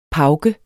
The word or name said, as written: pauke